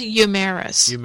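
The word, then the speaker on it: Euhemerus: Euphorius, I can't pronounce it, is a Greek scholar in the 4th century BC. – I think it's Eumerus.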